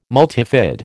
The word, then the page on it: multifid: American English